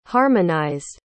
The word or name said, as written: harmonized